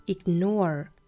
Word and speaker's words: ignore: 那英音和美音的区别呢，我们来看这句话里面 ignore, 美音读成 ignore, ignore, 也就是尾部的话呢，会有一个卷舌的